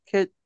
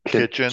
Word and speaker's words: kitsch: Another word is kitsch. I, I don't know if I'm saying it right. It's K I T S C H kitsch. – Kitsch. – Kitsch. – Like kitchen. Kitch. Only it's kitsch.